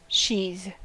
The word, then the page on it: sheathe: American English